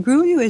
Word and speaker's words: Grullo: No, it Gruyo is Gruyo. – Gruyo is Gruyo. – Yep. – How do you spell that? – Grullo. Gruyo. Mm-hmm. And of course, the a double L is like a Y.